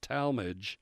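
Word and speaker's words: Tallmadge: Talmadge, that's T-A-L-L-M-A-D-G-E, pronounced Talmadge. Copley, C-O-P-L-E-Y, Copley Township. Buchtel High School, that's B-U-C-H-T-E-L, Buchtel High School, City Barberton.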